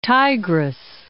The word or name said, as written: Tigris